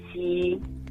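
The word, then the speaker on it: qi: Sema kama kwa mfano kuna sauti ya chi ambayo kwa Kiingereza inaandika na Q. – Na hiyo ni chi. – Chi. – Q-I inamaanisha chi ama kutamka kwake ni chi.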